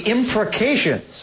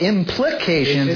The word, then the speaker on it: imprecation: And they call them the imprecations. I had even looked that up. It's one of those. – How do you say it? – Yeah, it's not implications. It's imprecation.